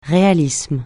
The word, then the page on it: réalisme: francais